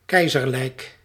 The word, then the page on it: imperial: /ɪm.ˈpɪəɹ.i.əl/; English